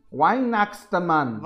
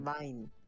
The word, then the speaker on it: Weihnachtsmann: Pronunciado como Wein, Weihnachtsmann. – Wein. – Wein. – Vine. – Ok, Wein. Eh, Dinamarca, Julmanden.